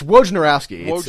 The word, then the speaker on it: Wojnarowski: It's Wojnarowski.